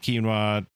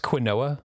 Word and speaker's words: quinoa: work on your potatoes and quinoa, you know, harvest what's available and plant what's left. – It's pronounced quinoa. Thank you very much. If you could use the correct one.